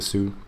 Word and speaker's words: Sioux: Am I pronouncing that correctly? The Sioux Nation? – Yeah.